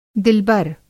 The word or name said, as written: दिलबर